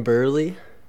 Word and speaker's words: Burleigh: It's Kevin Burley. And yeah, so. I'm Burley.